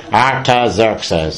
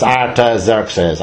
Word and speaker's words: Artaxerxes: That's the way you pronounce his name. I looked it up on the computer. Jacinta said, look it up, put it into Google, see how you pronounce it. And this girl said, Artaxerxes. So now I know. It's Artaxerxes. And the other fella is Xerxes.